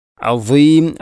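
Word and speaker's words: عظيم: അസീം പലപ്പോഴും നമ്മൾ പറയുന്നത്, പലപ്പോഴും എന്നല്ല അധികവും, നമ്മൾ അലീം എന്നാണ്, അധികം ആളുകളും അലീം എന്നാണ് പറയുന്നത്. അഥവാ സ്വാദിന് പുള്ളിയിട്ട ദ്വാദും, ത്വാന് പുള്ളിയിട്ടാൽ ഉള്ള ദ്വാദും, അതുപോലെ അള്ളാഹു എന്നതിലെയും ഉച്ചാരണമൊക്കെ നമ്മൾ ഒരുപോലെയാണ് പറയാറുള്ളത്. അത് ശ്രദ്ധിക്കണം. അസീം അസീം എന്ന് തന്നെ പറയണം.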